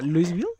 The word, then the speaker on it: Louisville: Ajá, ajá, Louisville. – Sí, no. Louisville.